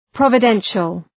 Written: providential

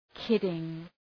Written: kidding

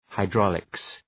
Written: hydraulics

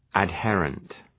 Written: adherent

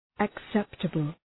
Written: acceptable

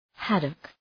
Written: haddock

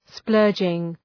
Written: splurging

